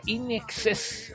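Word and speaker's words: INXS: Y saben ustedes que Inexe, pronunciado en inglés, significa in excess, ¿eh? O en exceso, ¿eh?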